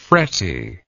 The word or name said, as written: fretty